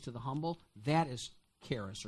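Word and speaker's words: charis (χάρις): The Greek word, now, the Blue Letter Bible pronunciation of that word is charis, but that's hard for me to say. So I'm just going to phonetically say it, charis or charis.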